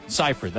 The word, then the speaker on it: Seyfried: Did you ever see the movie Jennifer's Body with Megan Fox and Amanda Seyfried? – Yeah. – No, I pronounced it wrong. Amanda Seyfried. Seyfried. That's how she pronounced it.